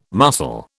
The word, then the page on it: muscle: British English